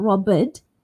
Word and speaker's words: robbed: So we've got the ending for b, robbed. So robbed, robbed. So not robbed, but robbed with a d sound. Rigged. R-I-G-G-E-D, rigged. So make sure you say the D sound at the end. L-O-V-E-D, loved.